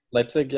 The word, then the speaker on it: Leipzig: Leipzig, yeah.